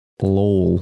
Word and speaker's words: Lowell: I was filming some RC cars up in, or down in Lowell. Is that how you say it? L-O-W-E-L-L.